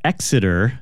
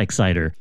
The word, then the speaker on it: Exiter: And we're going to start this one off with a band called Exeter. I'm pretty sure that's how it's pronounced, E-X-I-T-E-R, not Exciter.